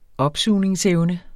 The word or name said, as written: opsugningsevne